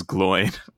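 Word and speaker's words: Glóin: Anyway, we had a whole conversation about like, yeah, it's definitely pronounced Glowin because the accent is on the O. And then in the audiobook, it's pronounced Gloin. So definitely Gloin. Yeah. – Yeah. As longtime knowers of how it was said out loud in the movie, we can confirm that it was Gloin.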